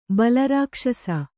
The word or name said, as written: ಬಲಾರಾಕ್ಷಸಿ